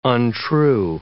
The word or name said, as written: untrue